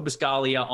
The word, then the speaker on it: Buscaglia: Everybody go follow him, Joe Buscaglia on Twitter. There's a G in there at the end of his last name, but the G is silent like lasagna.